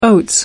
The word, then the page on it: oats: American English